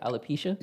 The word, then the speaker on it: alopecia: Alopecia? – How do you say it? Alopecia? – Yeah.